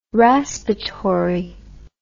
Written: raspatory

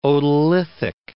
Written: otolithic

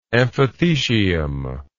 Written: amphithecial

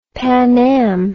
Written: Pan Am

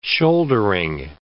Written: shouldering

